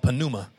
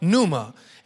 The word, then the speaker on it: pneuma: You know, I never know who is kind of newer to the church, who don't maybe know what pneuma means, or some of you call it pneuma. The P is silent, okay?